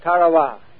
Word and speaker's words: Tarawa: Ladies and gentlemen, there's a tiny island in the mid-Pacific called Tarawa. That pronunciation, incidentally, is from the National Geographic Society.